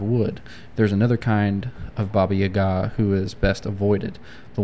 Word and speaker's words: Baba Yaga: It's Baba Yaga. I think it can be pronounced a few different ways, but that's how I've heard it the most.